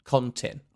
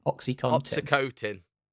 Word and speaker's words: OxyContin: Oxycontin. – Oxycotin.